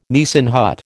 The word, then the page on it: Nissen hut: American English